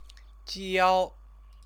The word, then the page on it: jiao: Chinese